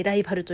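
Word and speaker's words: 好敵手: ライバルってさ、あの、何ジャンプ的な意味で言うとさ、公的種って書いてライバルと読むみたいなさ、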